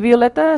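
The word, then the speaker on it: Violetta: Eso es, que vayan a Violeta Curry Music. Y Violeta se escribe con dos T's. Yo ya sé que en castellano es con una T, pero a mí me dieron el nombre con dos.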